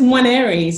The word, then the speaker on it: Nwaneri: It's Nwaneri, so it's almost like a silent N almost.